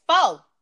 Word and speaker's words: pho: Is it called pho? – It's pho. It's pho. – It sounds like pho. It looks like pho.